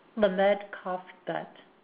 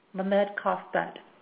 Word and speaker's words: Lamed Kaf Bet: And the way that this name is pronounced, the common name being finish what you start, but the key name here is pronounced Lamed Kaf Bet. Lamed Kaf Bet.